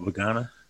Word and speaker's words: Vagana: Linda Vangana, but she got Vagana. – Vagana. That was one of the big ones back early days. Warriors. – Vagana. – Vagana. – Yeah. Nigel Vagana. – Yeah. Vagana.